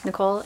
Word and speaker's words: Nicole: Nicole McKittrick. Nicole, N-I-C-O-L-E, McKittrick, M-C-K-I-T-R-I-C-K.